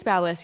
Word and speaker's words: BaByliss: And then I've had good luck with Babyliss as well. – Oh, I went to the like. – Am I saying that right? – Yeah, Babyliss? I think it's Babyliss. Yeah.